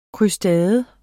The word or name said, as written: krustade